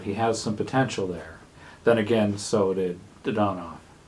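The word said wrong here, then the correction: Dadonov: Dadnov. I can't even get the pronunciation right on the first try in his farewell address.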